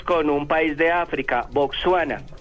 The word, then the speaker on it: Botsuana: En inglés se escribe así, con W después de la S, pero en español no lleva esa W, sino la U, tal como suena, Botswana, B-O-T-S. U-A-N-A.